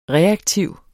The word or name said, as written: reaktiv